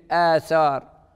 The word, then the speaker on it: آثار: ف فتبدل الهمزة الساكنة ألفاً وتصبح في النطق الفعلي آثار.